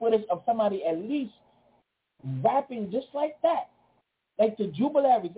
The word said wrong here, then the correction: Jubilaires: Am I saying it right? The Jubilaires. The Jubilaires.